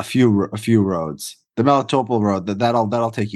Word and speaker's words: Melitopol: Well, yeah, there, there's kind of driving south through Zaporizhia, going through uh, how do you pronounce it, Melitopol, uh-huh. Melitopol.